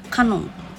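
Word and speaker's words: 鹿音: でも今あのスタンド FM でも活躍なさっている「鹿に音」と書いて「カノン」と読む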